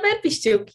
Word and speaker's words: Verbishchuk: Ich bin Malvina Werbistück.